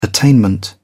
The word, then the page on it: attainment: British English